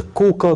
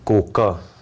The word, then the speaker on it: Коука: То есть есть музей ниндзя в городе Ига, есть целых два музея на территории вот уезда Коука, который в популярных книжках обычно называют Кога, хотя местные жители не звончают этот слог, да, бьют себе пяткой в грудь, говорят, наше родное селение читается как Коука, да, но, видимо, по аналогии с Иго, сами японцы стали читать так.